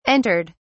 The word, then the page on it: entered: British English